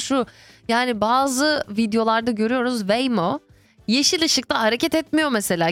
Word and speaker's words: Waymo: Waymo diye telaffuz ediliyor.